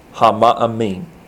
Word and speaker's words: hama'amin: the word "believes" in Hebrew is the word hama'amin. Hama'amin. If you want to spell it, it's H-A-M-A apostrophe A-M-I-N. Hama'amin.